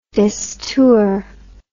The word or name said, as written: destour